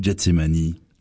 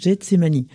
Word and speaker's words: Gethsémané: Les Bibles protestantes le traduisent Gethsémané, alors que les Bibles catholiques le prononcent Gethsémani.